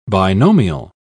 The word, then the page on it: binomial: British English